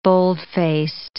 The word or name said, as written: bold-faced